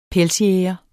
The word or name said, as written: pelsjæger